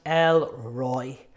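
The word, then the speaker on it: El Roi: In Hebrew, it's pronounced El Roy, the God who sees.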